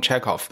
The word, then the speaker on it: Chekhov: 今天是我们的调戏栏目，我们聊俄罗斯剧作家契诃夫啊，这是官方的发音 Anton Chekhov，